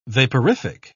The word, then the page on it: vaporific: British English